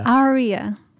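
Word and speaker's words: Arya: Ira, it's right there. – Aria, something like that. – Aria. – I knew I was saying it wrong.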